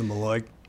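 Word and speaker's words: malocchio: But it does seem like they have the Maloikio, or the Maloik, as my mom calls it. – The Maloik? – Yeah. The Italian curse. Mm-hmm. It's spelled Maloikio. – Right. – But the Italians call it Maloik.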